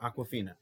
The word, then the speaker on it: Awkwafina: So I'm pronouncing it Awkwafina. Yes, it sounds like the water.